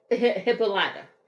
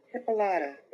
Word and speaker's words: Hippolyta: Hippolyta. – Hippolyta.